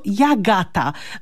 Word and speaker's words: Agata: a więc ta Agata była w gwarach wymawiana jako Jagata.